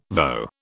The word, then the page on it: voe: British English